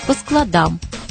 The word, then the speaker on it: складам: Если же речь идет о навыках того, кто пока плохо читает, то ударение другое – читать по складам.